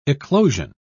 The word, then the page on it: eclosion: British English